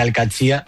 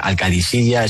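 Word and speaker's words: Al-Qadsiah: Bueno, se suele decir Al-Qadsi, pero también hay gente que dice Al-Qadisiyya, es que es difícil, ¿eh? Ah, no es fácil, pero Al-Qadsi es lo más fácil.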